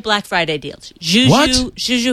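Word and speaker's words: Zhu Zhu: Z-H-U. – Oh, Juju.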